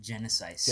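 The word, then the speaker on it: Genisys: It's spelled weird, right? – I've decided to pronounce it Genesis.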